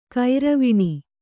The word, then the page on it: ಗೌರೀವೀಣೆ: kannada